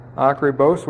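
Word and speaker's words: Acribos (ἀκριβῶς): It's a long O, Acribos, which means accurately or fully well.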